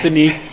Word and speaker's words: Denis: He's not going to like Dennis. It's Denis. – Denis, indeed.